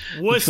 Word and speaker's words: Worcester: It is their penultimate year, 2020, in Pawtucket before moving to a city that I can't – that always corrects my pronunciation. – Worcester. Not that hard. – All right. – Worcester.